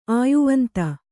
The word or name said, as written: ಆಯುವಂತ